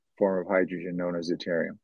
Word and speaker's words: deuterium: Deuterium. – Deuterium. – Deuterium, yeah.